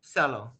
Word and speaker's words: Seliu: I'd say it rhymes with fellow. – Cello.